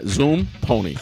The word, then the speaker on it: Zumponi: It's like a fast little horse. – Yeah, Zumponi. Fast horse.